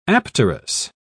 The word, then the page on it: apterous: British English